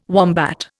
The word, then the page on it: wombat: Indian